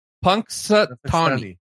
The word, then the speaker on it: Punxsutawney: Punxsutawney.